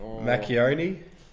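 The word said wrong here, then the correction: Marchionne: Let's have a look here, Sergio. Yeah, let's go. Marchioni. – Marchioni.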